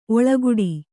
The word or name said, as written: ಓಲೆಗೊಡೆ